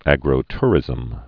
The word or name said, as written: agrotourism's